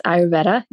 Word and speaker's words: Ayurveda: Is it Ayurveda? – Yes, Ayurveda. Yes, I do.